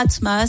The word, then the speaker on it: ATM2A: Enfin, regardez, on est là, Atmaa. Ça s'écrit ATM2A.